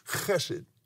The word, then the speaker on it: hesed: Hesed is the covenant. It's pronounced chesed.